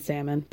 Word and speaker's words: salmon: You don't even know that there's a silent L in salmon.